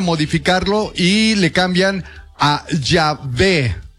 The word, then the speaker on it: Yahvé: Sería como Yahvé. Yahvé. No, no, y además la W es Yahvé.